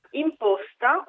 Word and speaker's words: imposta: Imposta o imposta, intanto entrambe le pronunce sono corrette,